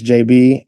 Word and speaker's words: Jabee: At my name is JB, and JB is spelled J-A-B-E-E.